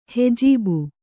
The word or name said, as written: ಹೆಜೀಬು